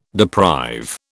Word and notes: deprive: British English

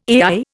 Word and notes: AI: Indian

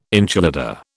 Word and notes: enchilada: British English

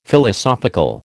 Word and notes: Philosophical: American English

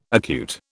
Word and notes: acute: British English

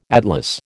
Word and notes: atlas: American English

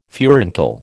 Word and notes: furuncle: American English

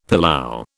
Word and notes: pilau: British English

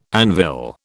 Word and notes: anvil: British English

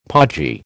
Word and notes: podgy: American English

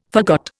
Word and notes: forgot: Indian

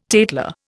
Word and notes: tatler: Indian

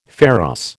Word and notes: pharos: American English

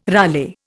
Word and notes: rale: Indian

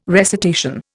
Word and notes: recitation: Indian

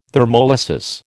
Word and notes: thermolysis: American English

